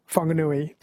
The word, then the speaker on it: Whanganui: I think the WH is pronounced F, like Whakatane is WH, Whanganui WH instead of F.